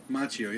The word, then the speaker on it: Macchio: Macchio, yeah.